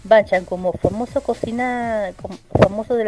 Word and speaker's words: banchan: Eh, pero se sirve directamente como banchan. Banchan, que para que, sepan, eh, es lo que yo, es lo que yo explicaba. Banchan, eh, pronunciado también como banchan,